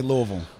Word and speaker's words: Louisville: If you say anything other than that, then you're not, you're an outsider. – You don't know. – You'll be alienated quickly. – You have to say it like you have molasses in your mouth. – Louisville. There you go.